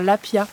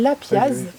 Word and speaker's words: lapiaz: Nous, on appelle ça un lapia. – Comme le poisson. – Comme le poisson. Je l'ai découvert tout à l'heure. Grâce à un pote à toi, qui connaissait le lapia, poisson. Alors ça s'écrit lapiaz.